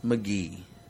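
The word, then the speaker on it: Magee: The last name is spelled Maggie, but it's pronounced McGee.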